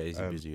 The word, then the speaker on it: Izzy Bizu: Yeah, so Best Newcomer, 6-7, AJ Tracy, Avelino, Mo Stack, Koji Radical, Nadia Rose, Ray Black, Anne-Marie Weston, and Izzy Bizu. I don't know if I'm pronouncing that correctly. – Yeah, Izzy Bizu, yeah.